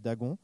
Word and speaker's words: Dagon: Dagon, je vais le prononcer à la française, Dagon.